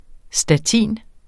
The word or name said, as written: statin